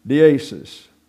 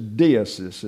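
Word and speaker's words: deesis (δέησις): Deesis. It's a deesis is the way it's pronounced. The accent is on the epsilon, not the eta. The eta gets an a, long A sound in Greek. It's deesis.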